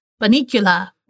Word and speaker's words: Bunnicula: I was like, is it like bunny and the end of Dracula? And she's like, yes. And then she sent me the cutest little pronunciation. – Yeah. – That said, Bunnicula.